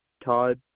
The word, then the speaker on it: Todd: Todd, TODD.